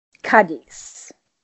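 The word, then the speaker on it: Cádiz: that I've been mispronouncing, I thought it was pronounced Cadiz, but it's Cadiz.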